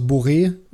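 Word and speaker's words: Borré: Der Mann heißt Rafael Santos Boré und nicht ah, Borre.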